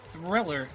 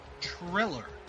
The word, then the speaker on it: Triller: It's not Thriller, it's Triller. There's no H in there.